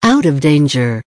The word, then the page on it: out of danger: American English